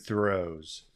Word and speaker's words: Thoreau: Henry David Thoreau's. – How do you say his name? – Thoreau. – Is that how you say Thoreau? Thoreau.